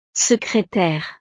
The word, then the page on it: secretaire: frances